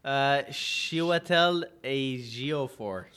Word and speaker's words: Chiwetel Ejiofor: Oh, Chiwetel Ejiofor.